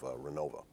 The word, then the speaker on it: Renovo: In one of the sections that were surveyed, and this is up around, I think, the village of Renova, which is actually spelled Renovo, but you don't want to pronounce it that way.